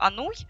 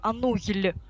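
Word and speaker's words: Anouilh: Фамилию Ануй на афишах МХАТа писали Ануй, а на афишах Малого театра писали Ануйль. Потому что, собственно, во французском вот это сочетание «и» и удвоенного «л», оно действительно не читается. Оно читается как «е». И вот мхатовцы использовали способ транскрипции, а Малый театр использовал транслитерацию. Сейчас уже устоялась «ануй» именно по произношению.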